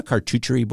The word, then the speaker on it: charcuterie: Do you know a charcuterie board? How do you say that? – Charcuterie. – Charcuterie. Yeah, something like that.